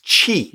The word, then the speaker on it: Qi: So these chargers, the technology is called, I think it's pronounced Qi. – Yes. – Qi.